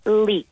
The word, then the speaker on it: leek: E si scrive L-E-E-K. Il problema con questa parola è che esiste un'altra con la stessa pronuncia scritta in modo diverso. L-E-A-K che significa perdita.